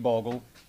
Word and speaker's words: Bogle: I'm Steve Bogle. That last name is spelled B-O-G-L-E, Steve's common spelling.